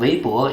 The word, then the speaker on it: Weibo: Also eigentlich, ich bin ein super royaler Nutzer von Weibo. Ich nutze Weibo täglich vielleicht 2 Stunden. Weibo funktioniert mir als eine erste Quelle für was in China passiert ist, weil ich in Deutschland lebe.